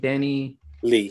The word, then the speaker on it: DaniLeigh: Danny Lee.